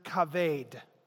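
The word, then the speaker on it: Kaved (כָּבֵד): The Hebrew word here for severe or grievous is the word kaved. I have no Hebrew or Greek training, but I listened to a guy pronounce it, so I know how to say it.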